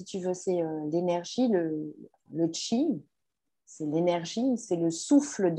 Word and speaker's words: qi: Bon, c'est qi, ça s'écrit Q-I, on est d'accord? – Oui. – Mais ça se prononce qi.